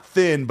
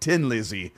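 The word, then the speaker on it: Thin Lizzy: Now, before I start this, because I just thought of this just now, I've been told most of my life that the official pronunciation of this band from Ireland is not Thin, but Tin Lizzy.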